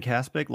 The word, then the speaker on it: Kaspick: They can find me on Twitter at Ben Caspick. Last name is spelled K-A-S-P-I-C-K.